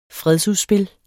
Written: fredsudspil

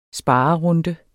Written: sparerunde

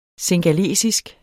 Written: singalesisk

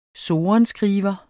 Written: sorenskriver

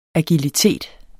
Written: agilitet

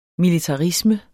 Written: militarisme

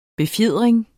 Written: befjering

